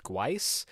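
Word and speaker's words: Guice: This past week, I learned that his last name is pronounced Gweiss, unless I got incredibly bad info.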